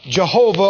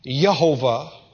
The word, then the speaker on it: Jehovah: Jehovah, or actually that's pronounced as a Y, Yehovah.